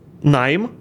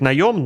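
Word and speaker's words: наём: И он себя включает и найм. Я знаю, что правильно говорить наем, но сорян, я не могу это заставить себя говорить.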